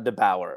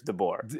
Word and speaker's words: DeBoer: It's not DeBauer. I'm pretty sure it's DeBoer.